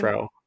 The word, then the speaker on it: Frau: Did you say Freyu? – What did I say? Well, what's your name? Frau. – Frau.